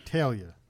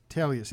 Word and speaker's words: Teglia: Teglia. Teglia. See, I keep butchering it. Because it's T-E-G. All I know is don't say the G.